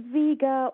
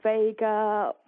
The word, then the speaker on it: Vega: Um, can you please explain what is a Vega machine, and am I pronouncing it right? – You are. It's well, it's it's Vega or Vega or probably you can call it anything you want really.